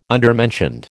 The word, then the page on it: undermentioned: American English